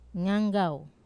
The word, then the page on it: nganggau: wamesa